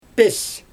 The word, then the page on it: pesJ: Xyzyl